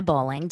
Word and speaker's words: Bowling: You can definitely find me over on Instagram at This Is Donna Bowling. Donna is spelled D-A-N-A and bowling is like the sport.